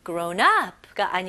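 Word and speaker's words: grown-up: 그리고 강세는 앞부분. Grown up가 아니고요. Grown up.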